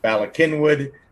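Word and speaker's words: Bala Cynwyd: So yeah, I tried pronouncing it the way Google Translate said it would be pronounced in Welsh, and apparently that's just not how it really is. I mean, and I get it. – Philadelphians say Ballakinwood.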